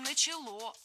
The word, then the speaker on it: начало: Усадебное строительство несколько позже начало развиваться активно, когда наступила вольность дворянская, то есть с 1862 года активное строительство начало вестись. – Не начало, а начало. Ударение в этом глаголе, опять же, не ставим на окончание.